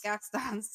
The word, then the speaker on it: Gaston's: Guston's Tavern in Disney. – Ooh. – Is that, did I say that right? – Guston's. Gaston's. – Gaston's, sorry.